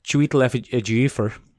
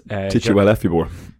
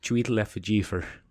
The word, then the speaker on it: Chiwetel Ejiofor: Chiwetel Ejiofor plays... – Tichuelefibor. – That's definitely not how you say it.